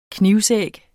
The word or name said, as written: knivsæg